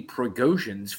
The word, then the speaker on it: Prigozhin: I know I definitely wouldn't have taken Yevgeny Prigozhin's fucking helicopter. This just in, this is also breaking news. Jim, can you pull that? I'm impressed that you said his name right.